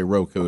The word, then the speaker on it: Roku: Now, they make fun of me when I say Roku.